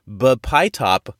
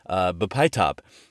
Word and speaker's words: bpytop: It's Bpytop, I think. Bpytop. B, capital P-Y, lowercase T-O-P.